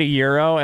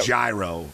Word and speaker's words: gyro: How'd you just pronounce that? – It's a euro, isn't it? – Euro. – Euro. Oh, whatever. Euro, euro. – Just don't say gyro. – Yeah. No gyro.